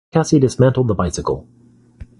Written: dismantled

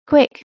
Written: Quick

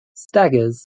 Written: staggers

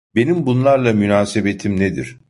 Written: nedir

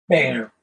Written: bayer

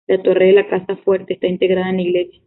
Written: integrada

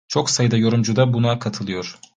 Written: buna

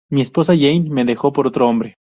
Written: esposa

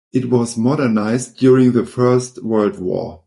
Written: modernized

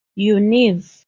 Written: Univ.

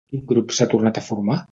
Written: tornat

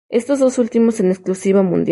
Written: exclusiva

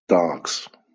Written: starks

